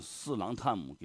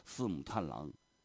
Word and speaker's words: 四郎探母: 就是你文化旅游局的副局长，你把这些传统的曲目，你念的错的都离谱，你四郎探母给念成个四母探郎，你这就不行呗，对不对？